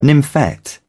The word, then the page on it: nymphet: British English